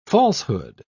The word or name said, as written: falsehood